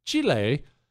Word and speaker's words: Chile: What, Chris? That's how you say it when you habla. Anyway, Chile.